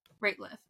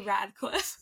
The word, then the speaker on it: Rateliff: Rateliff. Mm. I always want to say Radcliffe.